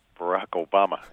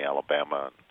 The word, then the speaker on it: Barack Obama: Barack Obama. And people mispronounce it and call me Alabama.